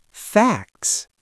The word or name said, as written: facts